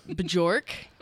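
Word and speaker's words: Björk: All right, as you heard, we have some beautiful music in the system from Bjork. – Whatever. That's how it's spelled. B-J-O-R-K.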